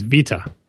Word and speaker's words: Vita: And the pronunciation is Vita. – Living la Vita Loca. – Yeah. Kind of sounds like Viva. He says that Japanese doesn't specify what syllable the emphasis is on, but it seems like it should be Vita.